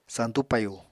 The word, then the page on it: santú payu: apatani